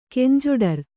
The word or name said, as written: ಕಂಜೊಡರ್